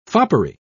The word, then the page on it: foppery: British English